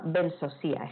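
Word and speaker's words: Benzozia: Pues ha aparecido hace como dos años o tres, eh, leyendo un libro, nos enteramos de que había una diosa vasca que se llama Bensosía, escrito Bensosia porque las zetas en, euskera se pronuncian como una S sibilante, que es la, diosa de, dragona de los Pirineos, que es el fuego interior de la tierra.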